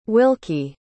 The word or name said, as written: wilkie